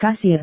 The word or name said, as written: kasir